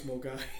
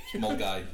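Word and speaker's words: Milngavie: Yeah, if you see someone spelled Milan Gavi, it's Mulgai. – Mulgai, yeah.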